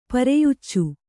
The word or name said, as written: ಪೆರೆಯುಚ್ಚು